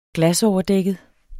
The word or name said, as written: glasoverdækket